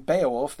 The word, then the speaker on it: Beowulf: If you ask somebody, especially an American, how many syllables there are in the name Beowulf, they would say three, right? Beowulf, right? But in Anglo-Saxon, it's a two-syllable word.